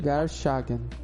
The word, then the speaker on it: Garshagen: Tá Garshagen. Garshagen. Bruno Garshagen. Como é que eu tava falando? – Falou Gargash. – Gargamel. – Ah, entendi. Tava falando errado mesmo.